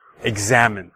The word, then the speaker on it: examine: So that's three syllables, and hopefully you can hear the stress on the second syllable, as the same as exam, examine.